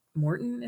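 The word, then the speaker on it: Maurten: But I used I think Morton is how you say it, mm-hmm. the Morton mm-hmm.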